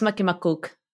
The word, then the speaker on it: Makimakkuk: No, it's Makimakkuk.